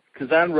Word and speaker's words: Kazan: You ended up in, is it pronounced Kazan? Kazan? – Yes. – Kazan, Russia.